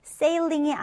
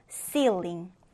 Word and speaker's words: ceiling: 자, 이 단어 발음 주의해 주세요. sailing이 아니고 ceiling. ceiling입니다.